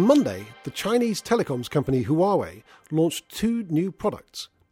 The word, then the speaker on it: Huawei: Well, Huawei, as it's pronounced, is known very well to our customers.